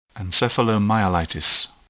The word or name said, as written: encephalomyelitis